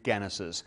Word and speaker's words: genus: I pronounce it genus because I was taught classical Latin when you have a hard G. If you were taught church Latin, as they call it, you would learn a soft G and you might say genus or janus. But I learned it the other way, so I still call it genus because I'm a stickler for my classical Latin.